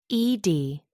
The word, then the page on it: ed: American English